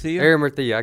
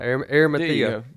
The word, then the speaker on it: Arimathea: You know, I think about the life, what little we know in Scripture of the life of Joseph of am I pronouncing that right? – Arimathea. – I couldn't get that out. Arimathea.